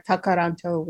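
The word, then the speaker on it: Tkaronto: I'm joining you here from Toronto, Ontario, Canada, or Tokoronto, which is how you say it in the Mohawk language.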